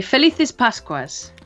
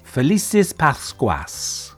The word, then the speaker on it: Felices Pascuas: You would say Felices Pascuas. – Felices Pascuas. – Felices Pascuas, yes.